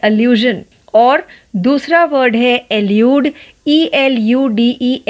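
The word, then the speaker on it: elude: first वर्ड की spelling है A double एल यू डी ई अल्यूड और दूसरे वर्ड की स्पेलिंग है ई एल यू डी ई एल्यूड अल्यूड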